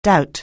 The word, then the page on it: doubt: anglais